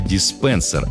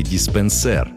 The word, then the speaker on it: диспенсер: Почему же «диспенсер», а не «диспенсер»? Все просто. Это слово пришло к нам из английского языка и сохранило соответствующее ударение.